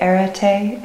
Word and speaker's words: arete: And speaking of philosophy, is it arete? Is mm-hmm. that how you pronounce it? – It is.